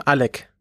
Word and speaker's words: Oleg: Also, also, der russische Name Oleg, den kennt man ja. – Ha? – Und der heißt aber äh, richtig ausgesprochen Alec.